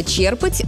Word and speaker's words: черпать: У глагола «черпать» ударение устойчивое, оно сохраняется и в других формах. «Черпаю», «черпаешь», «черпают», «черпал». Само слово знакомое.